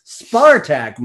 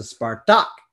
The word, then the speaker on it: Spartak: And the common problem with English speakers pronouncing Russian names is the stressing of the wrong syllable. Spartak Moscow as opposed to Spartak, but we are used to it now.